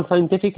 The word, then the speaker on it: scientific: طبعا scientific اللي هي السي اللي هي بعد الاس ديت silent، السي بعد الاس silent خلاص كده، والسي الأخيرة تنطق كي scientific خلاص كده.